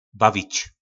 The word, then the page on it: bavic: po nasimu